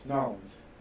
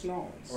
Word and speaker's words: New Orleans: How do you say it? – Nolens. Yeah. – Because it's either New Orleans. – It's just Nolens.